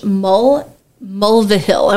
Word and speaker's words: Mulvihill: and that is by Josh Mulvihill. I want to make sure I say that right. Mulvihill, M-U-L-V-I-H-I-L-L.